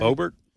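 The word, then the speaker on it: Boebert: You know, Lauren Boberts, is that the way you pronounce her last name? – Bobert? – Yep. – Bobert, she's the gunslinger.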